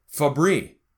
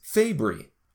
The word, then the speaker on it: Fabry: And everything that I had read had Glenn Fabry having his last name pronounced Fabry. Everybody at the convention even said it that way. And then, like I said, I looked it up. So I go over to do the interview and I pronounce it that way and he corrects me that it's Fabry.